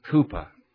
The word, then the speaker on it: Cowper: His name is spelled Cowper, but pronounced Cooper.